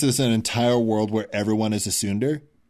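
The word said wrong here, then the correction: tsundere: I think that's pronounced Sundere. – Yeah, it is.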